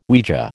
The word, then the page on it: Ouija: American English